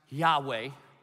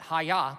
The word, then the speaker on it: Yahweh: In English, if you grew up in Dillon, it's pronounced Yahweh. Some like rabbis pronounce it Haya, but it reminds me of karate. It throws me off, so I don't think his name is Haya, okay? It's just Yahweh.